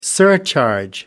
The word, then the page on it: surcharge: American English